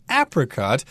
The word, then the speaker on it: apricot: And we should also mention that this fruit, apricot, can also be pronounced apricot. It uh, depends on your preference there.